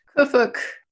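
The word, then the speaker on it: Qfwfq: The narrator, how do you pronounce the narrator's name? – Kufuk. – It's Q-F-W-F-Q, but I'm going to just refer to this character as Q.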